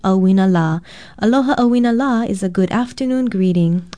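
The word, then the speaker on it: Auina La (ʻauinalā): Aloha, I'm Leilani Poliahu for Hawaii Public Radio, and our Hawaiian word of the day is Auina La. Auina is spelled Okina, A-U-I-N-A, and La is spelled L-A with a kahakou or makron over the vowel.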